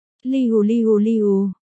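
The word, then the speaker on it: liu liu liu (六六六): 666, trong tiếng Trung cụm số 666 được viết là liu liu liu và cách phát âm của từ này sẽ là liu liu liu, tuy nhiên cách đọc của từ này lại có phần tương đồng với chữ niu phát âm là ni ú.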